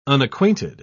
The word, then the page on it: unacquainted: British English